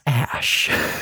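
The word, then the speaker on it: Æ: So that that little like combination AE thing. – Yeah. – It's pronounced Ash.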